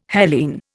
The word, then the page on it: Hellene: Indian